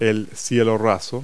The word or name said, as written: ceiling